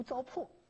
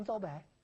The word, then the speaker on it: 糟粕: 我们都偷偷的笑他这个读白字儿，啊，后来才知道他不是不知道这个词儿读糟粕，他就是把他喜欢把它读成糟白，知道吧？